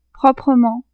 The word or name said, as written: proprement